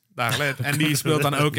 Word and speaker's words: Dagelet: ja, Dagelet of zo. Ik weet nooit hoe je zijn naam moet uitspreken. – Hans Dagelet.